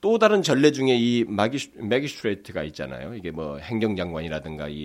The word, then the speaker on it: magi: 이 동강 박사들은 어, 어, 당시로 보면은 그때 당시로 보게 되면 이 옛날 사회에서는 그 에, 동방 박사를 영어로 마귀라고 합니다. M A G I 마귀가 아니라 이게 발음상으로 마귀라고 하는데 그래서 마기라고 하는 이 앞에 어근이 붙는 뭐 매직, 뭐 매지션, 또뭐 매기스트레이트 뭐 이런 말 있잖아요.